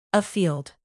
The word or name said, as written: afield